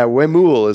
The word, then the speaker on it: Huemul: Yeah, Huemul is how they pronounce it.